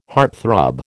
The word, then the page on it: heartthrob: American English